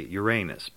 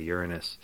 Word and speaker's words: Uranus: Uranus, which is a planet most people mispronounce. Some people say Uranus, but it's much better to say Uranus.